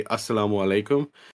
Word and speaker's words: assalamu alaikum: They use like words like the way that the cousins greet Kamala when she comes to off the airplane, there's like the greeting is technically assalamu alaikum, but the way she says it is very Pakistani. She says assalamu alaikum, which is like a sped up version where you only say it like that if you are natural, like that's how you're used to saying it.